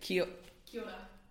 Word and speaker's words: Kia ora: Kia ora.